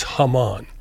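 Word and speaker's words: jamón: But the it's spelled J-A-M-O-N, but it's Haman.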